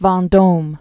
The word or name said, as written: Duc de Vendome